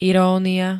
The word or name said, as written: irónia